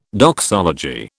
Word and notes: doxology: British English

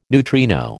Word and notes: neutrino: American English

